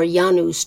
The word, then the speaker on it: Janus: The month of January was named for the Roman god Janus, or Janus to give it its Latin pronunciation.